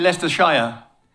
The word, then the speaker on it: Leicestershire: In fact, I was at the first one in Leicestershire. Am I saying it right? Leicestershire. – I think you say Leicestershire. – Yeah, we say Leicestershire.